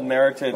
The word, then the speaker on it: Meritage: It rhymes with heritage, doesn't it? – Yeah, it's a 2012 Meritage.